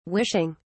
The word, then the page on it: wishing: British English